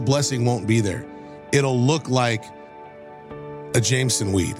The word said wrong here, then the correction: Jimson weed: Jimson weed.